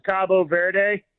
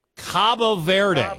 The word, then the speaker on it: Cabo Verde: They in Spanish, it's Cabo Verde. – Cabo Verde. – Cabo San Lucas or whatever in Mexico.